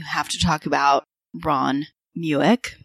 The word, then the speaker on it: Mueck: It said Muick. – That's right. Yeah. Muick.